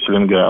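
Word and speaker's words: Селенга: Дело в том, что река Селинга, правильно говорит Селинга, она является 60% водосборной части Байкала, несет своих вод.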